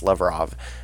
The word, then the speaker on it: Lavrov: That's what his name is, Lavrov.